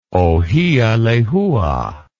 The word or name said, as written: ohialehua